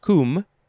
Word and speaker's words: cwm: It's pronounced cum, C-W-M, noun from 1853.